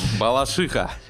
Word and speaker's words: Балашиха: Все, Балашиха. Э, ударение на «и».